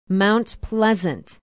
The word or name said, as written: mount pleasant